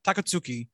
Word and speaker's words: Takatsuki: Like the conversation that Yusuke has with Takatsuki. Oh, I said it right the first time. Takatsuki.